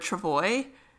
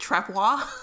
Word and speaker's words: travois: So, Ahako sends Harlow off to make a travois, and Mason Lloyd cannot pronounce the word travois, and he pronounces it trepois.